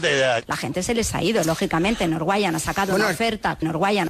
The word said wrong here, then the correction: Norwegian: Con Norwegian.